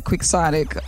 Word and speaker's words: quixotic: Now, just for the giggles of it all, I should spell it. It is Quixotic. So if you see it in writing, you might not put that sound right to those letters, but that's what it is.